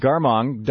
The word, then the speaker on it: Garmong: In any case, am I pronouncing your last name right, Garmong? – Yes. – Yeah, okay. Thank you. Robert is with the Ayn Rand Group, A-Y-N-R-A-N-D, the Ayn Rand Institute.